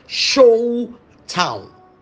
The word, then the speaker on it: Sholtan: Okay, not reign, R-A-N, R-A-I-N, no, reign, R-E-I-G-N as a king. Good. Then we have another word called Sholtan. It's spelled as Sholtan. S-H-O-L-T-A-N. But it's pronounced as Sholtown. Sholtown. Like you have a town.